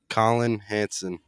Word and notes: Colin Hansen: male

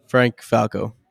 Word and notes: Frank Falco: male

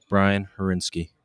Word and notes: Brian Harinski: male

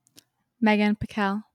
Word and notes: Meghan Pickel: female

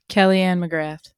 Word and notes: Kelly-Ann McGrath: female

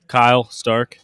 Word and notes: Kyle Stark: male

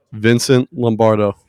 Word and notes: Vincent Lombardo: male

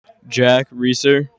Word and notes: Jack Reiser: male

